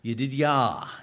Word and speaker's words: Yedidiah: Yedidiah is the Hebrew pronunciation.